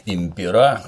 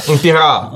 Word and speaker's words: impera: Применительно к которым известная присказка «divide et impera», то есть «разделяй и властвуй». – Impera. Прекрасно. На последний слог в латинском ударении ставить нельзя. – Я как француз. – А, импера!